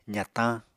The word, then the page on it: zatañ: apatani